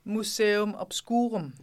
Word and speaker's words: Museum Obscurum: Museum Obscurum.